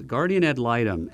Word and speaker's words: guardian ad litem: Guardian ad litem? – Close. Guardian ad litem.